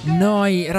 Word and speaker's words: Neu: Noi Radio Neu. – Si scrive Neu si pronuncia noi e vuol dire nuova, nuova emittente urbana da Bologna.